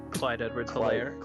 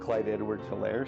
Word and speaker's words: Clyde Edwards-Helaire: Clyde Edwards-Helaire. – Clyde Edwards-Helaire, CEH.